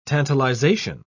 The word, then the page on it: tantalization: British English